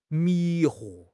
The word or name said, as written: 迷惑